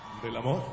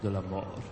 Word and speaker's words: de l'amour: How do you say that, again? – De l'amour. Oh, de l'amour. That's right.